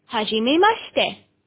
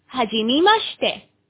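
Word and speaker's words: はじめまして: رددوا ورائي: هاجيمي ماشتي، هاجيمي ماشتي.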